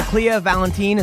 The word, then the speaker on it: Kleio Valentien: Oh, by the way, people at home, so Kleio Valentien, is that I'm saying it right? – Yes, correct.